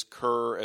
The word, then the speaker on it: Ker: Well, because it's spelled K-E-R, but the intention is Kerr, as in, like, looking down on a dog.